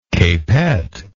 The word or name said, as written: capette